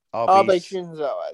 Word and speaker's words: Abe Shinzo: Abe Shinzo, actually, if you're going to pronounce it.